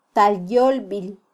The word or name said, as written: talyolbil